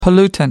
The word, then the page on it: pollutant: American English